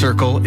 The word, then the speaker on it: CRCL: CRCL, pronounced Circle, is a free mobile crisis response service serving the Comox Valley.